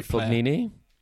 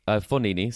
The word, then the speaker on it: Fognini: We have Fabio Fognini. Fognini, sorry, I said that wrong.